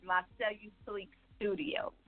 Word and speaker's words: La Cellu Sleek Studio: Yeah, it's actually pronounced La Cellu Sleek Studio.